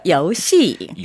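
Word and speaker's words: 요시 (游戏): 발음은 이성, 사성 해서 요시입니다. – 네, 좋으셨어요. 요시.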